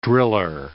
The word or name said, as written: driller